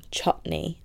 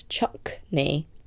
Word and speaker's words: chutney: But Melissa was pronouncing chutney as chuckney with a k in it.